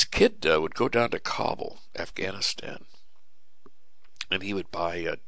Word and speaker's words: Kabul: I guess you pronounce Kabul. The whole thing is a big Jewish cabal, actually. But it's pronounced Kabul.